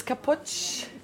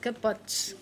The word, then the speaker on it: capoç: So, everything around me, this is Capoç. – Capoç. – Did I say that correctly? – Yes, yes, correctly.